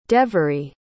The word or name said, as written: devery